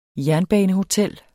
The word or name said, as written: jernbanehotel